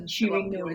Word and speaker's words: Shireen Noor: Shireen Noor is SHIREENNOOR.